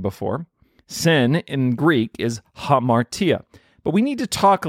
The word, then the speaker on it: hamartia: Today's Greek word is hamartia. You got to get that H sound at the beginning. Hamartia.